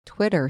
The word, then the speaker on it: Twitter: Twitter, sorry, 他是 short I, 我刚说 Twitter, Twitter。